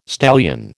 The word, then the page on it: stallion: American English